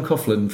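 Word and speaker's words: Coghlan: So I've got John Coughlin from Status Quo. That's how you pronounce his name.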